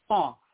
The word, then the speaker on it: Qaf: Let's go to the back of the throat in pronunciation. Almost like golf, but it's not golf, like we play the game golf, but it's Suratul Qaf, Qaf, Qaf.